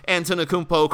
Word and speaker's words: Antetokounmpo: And yes, damn it, I spent about 30 minutes learning how to pronounce that dude's name. He's been in the league for years, and I'm finally getting Antetokounmpo correct.